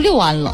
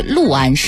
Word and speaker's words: 六安: 我可能太那好，对我好像念成六安了，对不起啊，读错了。应该是六安市